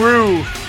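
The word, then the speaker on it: Rioux: Johnny Rue on bass. – Johnny Rio. – I always say his name wrong, don't I? – Call him Rue.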